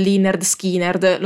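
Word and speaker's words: Lynyrd Skynyrd: Si parlava in particolare dell'arrivo di Freebird dei Lynyrd Skynard, sì, lo so che si dice Leonard Skinnerd, lo dice anche l'album di esordio che contiene questa canzone, ma come per i Ramones li ho sempre chiamati così e ripudio la pronuncia corretta.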